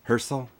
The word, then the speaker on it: Hersel: My name is Dean, D-E-A-N, Hersel, H-E-R-S-E-L. – H-E-R-S-E-L? – E-L.